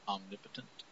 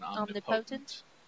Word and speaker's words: omnipotent: And by the way, this is Stan Lee, Jack Kirby, Vince Coletta, and Sam Rosen If I may suggest... an alternate pronunciation. I believe you may hear the word more frequently pronounced omnipotent rather than omnipotent. Yes. – Excuse me. – Omnipotent, I believe.